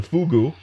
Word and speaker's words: fugu: Oh, is that how you say it in Japanese? Pufferfish still, or is it fugu. – Another? Fugu. – Fugu, yes.